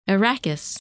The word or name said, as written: Erakis